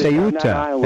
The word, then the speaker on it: Ceuta: Because, the fact of the matter is, you have people in Spain, in Cueto, right? In that country right there. Where you have the Ceuta. It's supposed to be pronounced Ceuta. I read that.